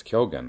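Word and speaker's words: Kyōgen: whereas Kyogen it's Kyogen, that's how you pronounce it, that's how they pronounce it in Japan.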